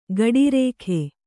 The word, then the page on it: ಗಡಿರೇಖೆ: gaḍirēkhe